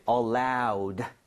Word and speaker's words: allowed: 그런데 주의해야 될게 많은 분들이 allow라고 잘못 발음하시는 경우 많아요. – 네. – 근데 얘는 allowed가 Allowed. – 되겠습니다. 알아도 연음해서 틀릴 수 있으니까 주의하셔야 돼요.